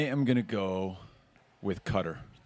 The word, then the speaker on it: Qatar: The Rabble podcast listeners are all over Texas, but also there are 19 in Qatar, which I always pronounce Qatar, and recently learned is incorrect. Qatar.